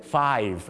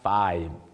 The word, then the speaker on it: five: หลายคนยังพอชอบพูดไฟไม่มีตัวสะกดนะฮะเดี๋ยวเขานึกมาจากดอยนะฮะต้องเป็น five เหมือนเวลาที่คุณแอปแบลถ่ายรูปครับ five